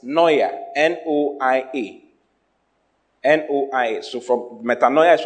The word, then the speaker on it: metanoia: The word repentance is from the Greek word metanoia. Metanoia. It's spelled M-E-T-A-N-O-I-A. M-E-T-A-N-O-I-A. – Wow. – Hallelujah. – Hallelujah. – Repentance, what? Metanoia. M-E-T-A-N-O-I-A.